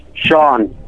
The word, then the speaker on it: Sean: Sean, Washington State.